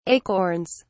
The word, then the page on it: acorns: British English